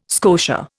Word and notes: scotia: Indian

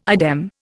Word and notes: idem: Indian

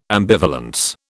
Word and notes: ambivalence: British English

